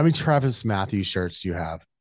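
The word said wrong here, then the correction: Mathew: Did I say that right? Is it Matthew or Matthews? – It's Matthew singular.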